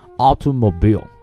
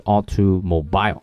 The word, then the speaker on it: automobile: 注意它的读音呢，读成 automobile 而不是 automobile 啊，这个你单独读 mobile 啊。它是读 i 的音啊，但是如果放在 auto 的后面的话，就变成 automobile。